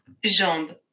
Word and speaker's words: jambes: Jambes.